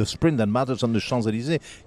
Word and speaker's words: Champs-Élysées: It's nice, Champs-Élysées.